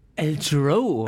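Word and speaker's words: Al Jarreau: El Jero wird da ausgesprochen. Yeah, El Jero.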